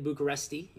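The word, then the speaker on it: București: He went to Rapid Bucharest, or Rapid Bucharesti, if you want to try and pronounce it in Romanian.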